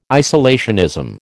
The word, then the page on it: isolationism: American English